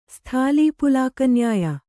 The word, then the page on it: ಸ್ಥಾಲೀಪುಲಾಕನ್ಯಾಯ: sthālī pulāka nyāya